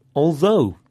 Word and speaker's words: although: This time we heard although, spelt A-L-T-H. O-U-G-H, and through, spelt T-H-R-O-U-G-H. Now, the O-U-G-H in although sounds like O, although.